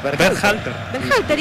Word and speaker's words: Berhalter: Dígalo usted. Berhalter. – ¿Y qué dije yo? – No, impecable.